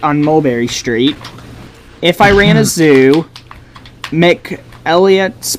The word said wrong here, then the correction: McElligott's: Pool. I don't know if that, or Elligott's Pool, McElligott's Pool. I don't know how you pronounce that, actually. – Elligott's, okay.